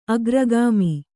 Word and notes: ಅಗ್ರಗಾಮಿ: kannada

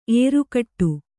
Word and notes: ಎರಗಾಡು: eragāḍu

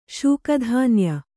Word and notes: ಶೂಕಧಾನ್ಯ: Kannada

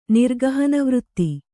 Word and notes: ನಿರ್ಗಹನವೃತ್ತಿ: Kannada

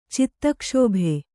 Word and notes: ಚಿತ್ತಕ್ಷೋಭ: citta kṣōbha

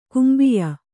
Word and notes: ಕುಂಬಿಯ: kannada

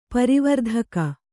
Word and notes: ಪ್ರವರ್ತಕ: Kannada

